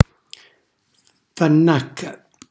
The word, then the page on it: fannàcca: calabrese